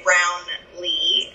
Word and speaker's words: Brownlee: Some people say Brownlee. It's actually named after my grandfather, Brownlee Currey. It's one full soup of a word.